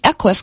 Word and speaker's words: Equus: That's the Equus computer system. We spell that E-Q-U-U-S, Equus, Latin for horse.